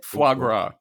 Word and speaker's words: foie gras: I want you to know we have foie gras. Is that how you say it? – Foie gras.